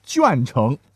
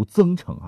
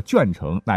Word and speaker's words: 鄄城: 那跟江苏紧挨着呢是山东啊，也是有很多这样的地名了，像鄄城。说实话，我一直以前读增城啊，才知道还有临渠，会经常被念作邻居。